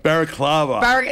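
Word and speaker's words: balaclava: Okay, but you're not going to know who it is because I'm going to wear like a baklava. Baklava, is that what it's called? I don't know if I'm pronouncing it correctly. Anyway. – Baraclava.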